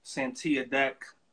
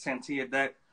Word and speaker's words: Santia Deck: Everyone who is just tuning in, my special guest tonight is Santia Deck. I'm pronouncing your name right? – You are. – I'm gonna make sure, okay. My special guest is Santia Deck.